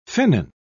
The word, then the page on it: finnan: British English